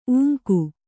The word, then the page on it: ಊಂಕೆ: ūŋke